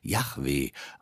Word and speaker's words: Yahweh: Im Hebräischen steht an der Stelle, wo Herr steht, J-H-W-H. Das wurde sehr wahrscheinlich Yahweh ausgesprochen.